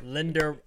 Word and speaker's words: Linderbaum: Key additions, Tyler Lindbron. That's said that wrong, but it's okay. Linderbaum.